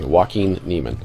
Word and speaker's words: Joaquin Niemann: Joaquin Niemann.